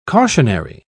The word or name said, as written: cautionary